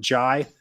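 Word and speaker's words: Ngie: So forward, I may say Kemba once in a while, but it's spelled K-A-B-B-A. And the last name is N-G-I-E. I've confirmed that you pronounce it Jai.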